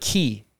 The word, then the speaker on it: quay: it's like key. It's spelled quay. – Quay. – Yeah, but it's key.